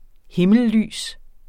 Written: himmellys

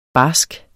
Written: barsk

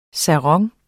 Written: sarong

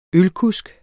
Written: ølkusk

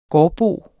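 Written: gårdbo

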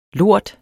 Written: lort

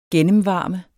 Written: GENNEMVARMES

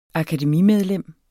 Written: akademimedlem

